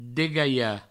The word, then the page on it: degalhar: Provençal; degaia